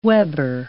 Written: weber

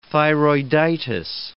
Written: thyroiditis

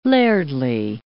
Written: lairdly

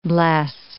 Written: bless

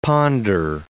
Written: ponder

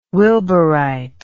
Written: wilburite

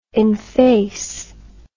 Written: enface

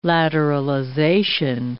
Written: lateralization

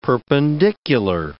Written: perpendicular

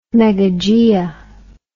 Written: megagaea

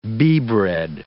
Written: beebread

